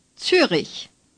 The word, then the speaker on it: Zürich: Zürich.